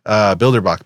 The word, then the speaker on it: Bilderbach: So it's MARC, Bilderbach, BILDERBACH.